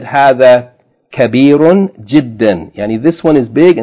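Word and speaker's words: جدًّا: The last point, number 5, جِدًّا, with شَدَّ on the del, جِدًّا, means very.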